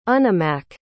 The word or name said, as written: unimak